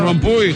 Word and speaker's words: Rompuy: S'escriu Rompuy, però es pronuncia Rompuy.